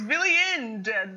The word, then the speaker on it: Villiand: I thought it ended with a T. – It's Villiand. With a D. – Fuck. – I think. – Villiand, yeah.